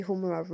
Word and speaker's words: Humura: My name is Mbabazi Humura Ruth.